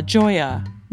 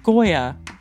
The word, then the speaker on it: Gioia: The former chairman of the National Endowment for the Arts is Dana Gioia, not, as Angela pronounces it, Goya.